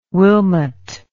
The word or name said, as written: Wilmot